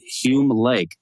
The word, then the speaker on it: Hume Lake: But Bethany grew up in a Christian family, and she met Jason in college at Hume Lake. Did I say that right? – Yeah. – Okay. For all I know, it could have been like Hume or something.